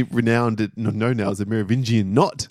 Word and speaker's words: Merovingian: But like, Merovingian, that guy, right?